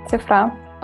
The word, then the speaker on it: CIFRA: Да, это только маленькая ремарочка, у нас ударение на «а», цифра.